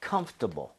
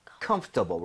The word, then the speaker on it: comfortable: 뭐냐면 comfortable, comfortable. 뭐냐면 여러분들 중간에 OR 보이죠? – 네. – 이거를 발음을 거의 안 하는 사람도 엄청 많습니다. 그래서 그냥 comfortable, right?